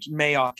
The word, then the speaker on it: Mayock: Yeah, but Mizzou instead went out and got, and I think it's Mayock is how you pronounce it, or Mayjock. – I think Mayock is correct.